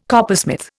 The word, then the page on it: coppersmith: Indian